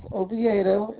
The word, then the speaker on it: Oviedo: My last name is Oviedo.